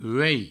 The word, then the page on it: uelh: uei